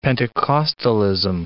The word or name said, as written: Pentecostalism